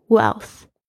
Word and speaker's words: wealth: But no wealth，但是没有财富， wealth， W-E-A-L-T-H， wealth，注意一下这个 E-S 发 E 的声音，然后加上 L 加上 T-H 的发音， wealth 不是很好念，但是一定要记住它的念法， wealth。